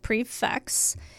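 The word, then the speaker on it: prix fixe: Simply put, a prefix menu, pronounced prefix, is a multi-course meal available for a fixed price.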